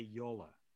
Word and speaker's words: Yola: So my name officially is in Dutch, Yola Kerpenstein. But what you see in Dutch and how you pronounce it is not exactly the same. So Americans tend to call me Jolly Kerpenstijen. And I have tried so many times. First, if it's J-O-L-L-E, they cannot say Yola.